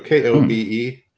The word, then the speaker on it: Kobe: Yeah, so K O B E.